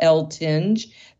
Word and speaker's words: Eltinge: My name is Patricia L. Tinge.